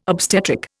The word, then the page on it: obstetric: Indian